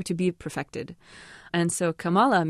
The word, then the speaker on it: Kamala: but it's K-A-M-A-L-A, and you pronounce it Kamala. – Kamala, yeah. – But a lot of people are used to seeing that pronounced Kamala.